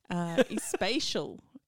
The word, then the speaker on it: Espacial: Anyway, the band is Trunco, and it's Mono Especial, and that's said so badly, Espacial, practically.